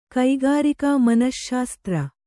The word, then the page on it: ಕಕ್ಕರಿಕೆ: Kannada